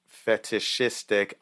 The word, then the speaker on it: fetishistic: So what we're talking about right now is called fetishistic. I'm going to butcher that probably. – No, you said it perfect.